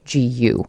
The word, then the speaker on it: Daegu: It's Daegu.